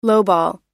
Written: lowball

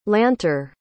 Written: lanter

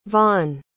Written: vaughn

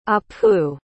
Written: apu

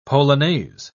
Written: polonaise

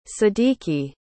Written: sadiki

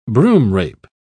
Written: broomrape

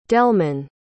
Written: delman

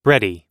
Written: bready

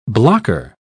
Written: blocker

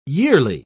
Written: yearly

